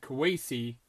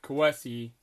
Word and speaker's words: Kwesi: Now that Kwesi, now learn that saying because he actually pronounces it Kwesi, not Kwesi, Kwesi.